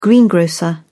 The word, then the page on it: greengrocer: British English